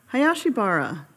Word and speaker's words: Hayashibara: Hayashibara, and that's the Americanized version. In, in Japan, they would say Hashibara. They would do a little flip of the are Hashibara?